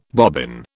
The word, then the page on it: bobbin: British English